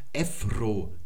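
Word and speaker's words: Ephro (ευρώ): Oder man sagt hier zum Beispiel Ephro, das ist also der Euro, die Währung.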